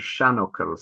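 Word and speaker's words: seanfhocal: Oh, these are what are called shanuckle. So shan being old, and buckle is actually just a word.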